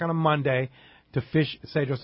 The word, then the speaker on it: Cedros: It's spelled Cedros Adventures with a C, C-E-D-R-O-S, but it's pronounced Cedros, right? – Correct.